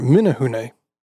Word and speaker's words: Menehune: The Tahitian settlers oppressed the commoners, the Manahune, in the Tahitian language, who fled to the mountains and were called Manahune, um, or sorry, Menehune.